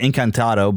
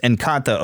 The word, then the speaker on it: Encanto: I keep wanting to call it Encanto, but it's just Encanto or whatever.